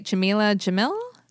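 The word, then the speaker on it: Jameela Jamil: That was about Rosario Dawson and, I hope I'm saying it right, Jamila Jamil? – Yes.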